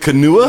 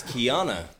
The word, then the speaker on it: Keanu: Is it Kanua? – No, it's Keanu.